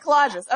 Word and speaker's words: collagist: Is it collagist? – Yeah, exactly. Collage. So you just say it's collage. – Exactly. Okay, collagist.